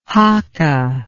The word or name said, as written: hakka